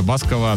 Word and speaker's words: Басков: Но перед тем, как это сделать, давайте поздравим Артура, который третьим по счету прислал правильный ответ на вопрос про Николая Баскова, а точнее про его оригинальную фамилию. Вопрос был с небольшим подвохом, многие поняли, некоторые нет. Дело в том, что в оригинальной фамилии этого артиста ударение на последний слог «Басков».